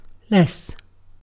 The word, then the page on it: less: British English